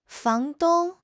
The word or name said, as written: fang2 dong1